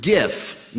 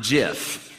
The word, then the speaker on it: GIF: But so number two is that it's pronounced GIF, not JIF. You know, the repeating video image, whatever, that it's supposed to be pronounced GIF. Now, this one I kind of understand because the acronym is Graphics Interchange Format. So the G in graphics needs to be the G in GIF.